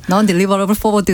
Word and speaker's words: non-deliverable forward: 넌 넌 딜리버러블 포워드. – 발음이 별로 안 좋아서 잘 안 들리네요. – 아, 제가 아주 코리안식으로 발음해 드린 건데 non-deliverable forward. 네.